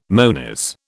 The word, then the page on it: monies: British English